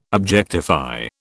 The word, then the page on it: objectify: British English